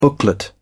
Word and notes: booklet: British English

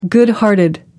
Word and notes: good hearted: American English

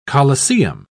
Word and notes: coliseum: British English

